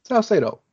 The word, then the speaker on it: Salcedo: Shout out to Denise Salcedo. Did I say her name right? Did I say it right? – I think it's Salcedo. I don't know. – Salcedo. Yes, Denise.